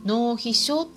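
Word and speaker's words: 膿皮症: でそれはですねワンちゃんの場合よくですね脳皮症と呼ばれたりしています。脳皮症というのは漢字で書くと「海」という漢字に「皮膚の「皮」っていう漢字に「症状の症」という漢字で脳皮症と読むん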